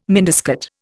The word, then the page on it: miniskirt: Indian